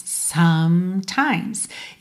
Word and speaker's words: sometimes: Você não fala sometimes, né? Então, sometimes. No fim das contas, você só tem duas vogais que são pronunciadas. O O da primeira sílaba, some, e o I lá na segunda parte da palavra, times, ok? Inclusive, essa palavra só tem duas sílabas, porque são só dois ataques, sometimes.